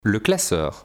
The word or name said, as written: le classeur